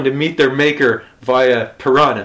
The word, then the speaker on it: piranha: as he calls them, which is the proper Portuguese pronunciation, piranha. And like, it's just so weird.